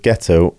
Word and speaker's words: ghetto: And you also mentioned that the word ghetto was born in Venice. – Yes, although probably with a soft G, originally ghetto, the foundry there.